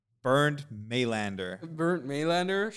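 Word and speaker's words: Bernd Mayländer: I'm saying his name wrong. Bernd Maylander.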